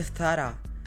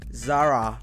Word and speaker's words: Zara: nunca te viste el TikTok de una color que decía, no se dice Zara, se dice Zara.